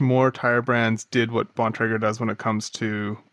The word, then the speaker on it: Bontrager: Bontrager is the correct pronunciation.